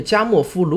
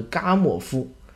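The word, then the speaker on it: Gamow: 也就是整个宇宙中应当残留着背景辐射，啊，这个加莫夫如果要用接近英文的发音来说的话呢，应该是嘎莫夫啊，但是我觉得听上去有点怪，我还是说加莫夫吧，大家知道就好。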